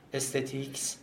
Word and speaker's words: استتیک: هنر یه بخششه یه دایره بزرگ استتیکس حالا ما تلفظ فرانسه رو معمولا به کار میبریم میگیم استتیک بدون اون اسه